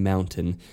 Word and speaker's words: mountain: I pronounce the T in mountain. – Right. – Unlike that is our biggest tell. Mountain. That's it. Glottal stop.